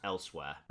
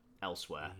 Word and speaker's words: Elsweyr: But moon sugar comes from where the Khajiit are from, which is how do you pronounce it? I think it's pronounced elsewhere. ELSWEYR, elsewhere.